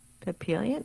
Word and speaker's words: Papillon: Papillon? Papillon? – Yes. – Mm. – And I pronounced it as a papillon, but it's papillon.